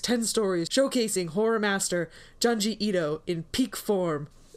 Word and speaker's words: Junji: Junji is how you say the first name.